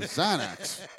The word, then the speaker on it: Xanax: Or Xanax. – Turner's what, 27, 28? – 20. – Xanax is with an X, but that's pronounced like a Z.